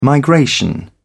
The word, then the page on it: migration: American English